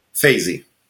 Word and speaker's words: Feizi: Is it Fayez? – Fayzi.